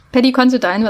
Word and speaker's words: Considine: Mit Paddy Considine. Considine, ach, schon wieder falsch ausgesprochen. Paddy Considine wird er ausgesprochen.